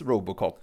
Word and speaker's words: Robot Coop: Ja, och sen kom det fram då att det är ju ett franskt fabrikat på den här som heter Robot Coop. Men det uttalas Robocop.